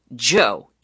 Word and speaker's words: Djo: He releases under the name Joe, even though it's spelled D-J-O.